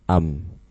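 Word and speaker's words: am (أم): Bacanya itu apa? Am. Bacanya am. Sama seperti maknanya seperti au, artinya atau. Tapi nanti ada sisi perbedaan dalam penggunaannya, dan bukan saat ini pembahasannya. Jadi am itu dibacanya am, artinya atau.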